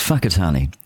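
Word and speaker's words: Whakatāne: I am saying Whakatane with a you know aspirated wh, which is how it should be pronounced, and they say no, it's Fakatani,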